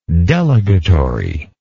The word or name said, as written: delegatory